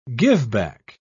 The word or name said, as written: giveback